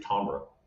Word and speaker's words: timbre: And it, if you're really pronouncing it correctly, it's actually Tombra. And Tombra is actually the correct way to pronounce the sound or the tone of something.